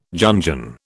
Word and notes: Jungian: British English

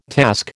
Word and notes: task: American English

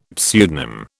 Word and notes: pseudonym: British English

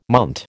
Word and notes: munt: American English

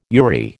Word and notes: URI: American English

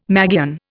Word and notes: magian: Indian

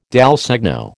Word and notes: dal segno: American English

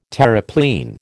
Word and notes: Terreplein: American English

